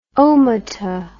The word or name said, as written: omata